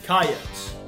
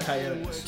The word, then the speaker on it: Coyotes: The South Dakota game notes say, we are Coyotes. Not Coyotes. – Two syllables.